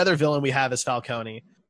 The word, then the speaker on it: Falcone: No, I like Falcone. – Either way is fine. – I'm Italian. That's how you're supposed to pronounce it.